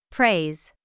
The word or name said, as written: praise